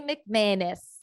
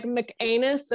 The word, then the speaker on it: McManus: So Girls Night gang, let's welcome Lily McManus. Did I say it right? – You nailed it. You make it sound like anus, like it's definitely McManus, but you're like McAnus, and I'm kind of all right with it.